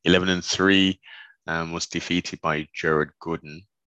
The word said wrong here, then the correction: Gordon: Or Gordon. I'm sure they were saying Gooden or something like that. It's Gordon, Jared Gordon, who's a tough little guy, man.